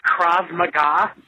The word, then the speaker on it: Krav Maga: Regarding the latest show, I just need to correct a pronunciation. It's actually called Krav Maga, and it's a form of martial arts.